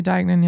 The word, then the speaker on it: Deignan: Und ähm, es war beeindruckend, wie Lizzie Deinen, ist, das richtig äh, ausgesprochen? – Ja, ich würde sagen Lizzie Deignan, ja.